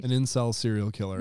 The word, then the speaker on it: incel: Incel, I-N-C-E-L. – Incel? Is that how you pronounce it? – Well, it's short for involuntary celibate. – So, that's what it's short for? – Yeah, so if you pronounce it like incel.